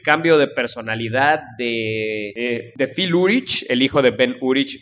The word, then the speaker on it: Urich: Yo le digo Urich porque siempre le he dicho así, pero realmente se pronuncia Urich.